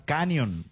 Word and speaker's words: Canyon: Canyon, se pronuncia, nos dice Pablo González. – Ahí está, Canyon.